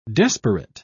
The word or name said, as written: desperate